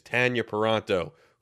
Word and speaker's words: Tanya Paronto: And yes, it is pronounced that way. I'm used to saying Tanya for everybody else with the same name spelling, but they will both insist to me it is Tanya Paranto for those keeping score at home.